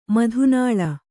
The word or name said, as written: ಮಧುನಾಳ